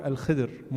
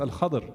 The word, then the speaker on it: Al-Khadr: Al-Khadr or Al-Khidr, more commonly actually pronounced Al-Khadr in Ahadith.